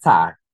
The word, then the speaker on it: Ty: No, it's Ty.